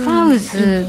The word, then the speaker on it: PAWZ: パウズ、はいパウズって読むんですか、ね、前足みたいな、パウズ